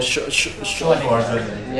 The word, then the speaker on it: Schwarzenegger: Arnold Schwarzenegger.